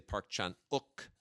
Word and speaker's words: Park Chan-wook: Also have Thirst, the Park Chan-wook film, which I know we're supposed to say Park Chan-wook.